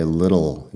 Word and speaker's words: little: Fíjate también en la pronunciación de little. Yo digo little, la doble T ahí como si fuera una D. – Little. Eso es en inglés americano.